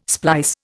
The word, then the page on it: splice: Indian